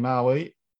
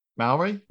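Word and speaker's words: Māori: How to say New Zealand in Maui. Maori.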